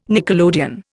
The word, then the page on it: nickelodeon: Indian